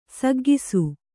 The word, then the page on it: ಸಗ್ಗಿಸು: saggisu; kannada